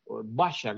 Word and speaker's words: Basia: Y esta canción de Basia, Basia, que es como realmente se pronuncia pues por acá,